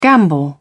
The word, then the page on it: gambol: British English